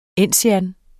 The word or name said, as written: ensian